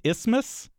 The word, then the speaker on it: isthmus: So it's just isthmus? – Yes. – Yeah, TH is silent.